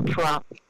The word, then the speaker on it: Truong: I'm Dr. Susan Truong. The last name is just one syllable, Truong.